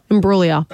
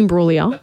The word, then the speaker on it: Imbruglia: Soft G. – Imbriglia. – Bang. – Imbruglia. Natalie Imbruglia.